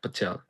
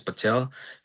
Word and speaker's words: Patel: My name is Bhavya Patel, B-H-A-V-Y-A. Last name is Patel, P-A-T-E-L.